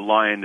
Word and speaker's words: Lyon: I think probably you're right, but Lyon is probably the way we today would pronounce it.